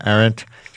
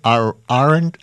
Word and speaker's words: Arendt: You say Arendt? Arendt. I've always said Hannah Arendt, but that's probably from total ignorance. – I don't know if I'm saying it right. – Hannah Arendt. I'm going with yours.